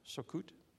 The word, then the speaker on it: Sukkot: I think it's pronounced Sukkot. Is that how you pronounce it? Sukkot? That's the Hebrew way to pronounce the word, Sukkot.